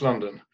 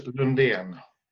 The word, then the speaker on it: Lundén: The English pronunciation, pretty much London. In Swedish, Lundén.